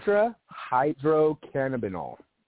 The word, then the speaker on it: tetrahydrocannabinol: Delta 9 Teletadacannabinol. Was I even close? – Tetrahydrocannabinol. – Tetrahydrocannabinol.